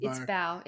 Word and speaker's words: Bao: I think it's Bao. – It is. Bao. It's Bao.